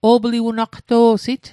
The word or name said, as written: o'pliwenaqto'sit